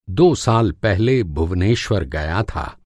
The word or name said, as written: भुवनेश्वर